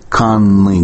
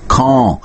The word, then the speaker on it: Caen: Конечно, пишет Виталий Костомаров в своей статье уже 30 с лишним летней давности, конечно, тогда начинается смешение с другим французским городом Кан, который пишется C-A-E-N или E-N, да, по-французски, и который расположен в Нормандии, и на слух русский человек не будет отличать один этот город, или даже в написании не будет отличать один город от другого, разве что там будет разное число «н».